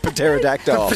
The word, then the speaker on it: Pterodactyl: Pterodactyl.